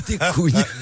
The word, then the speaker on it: couignes: Des cougnes. Des cougnes, des couignes. oui, pardon, j'ai mal prononcé.